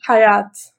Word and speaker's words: Hayat: Não, desculpa, como é que pronuncia seu nome mesmo que você falou aí né, recentemente? Hayat. Hayat. Hayat.